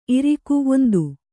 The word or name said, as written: ಇರಿಕೆ